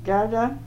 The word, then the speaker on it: Gerda: Gerda Gutterberg Fagerstrand. – Okay, would you spell that, please? – G-E-R-D-A, and my maiden name was Gutterberg, G-O-T-T-E-B-E-R-G, and my married name is Fagerstrand, F-A-R-E-S-T-R-A-N-D.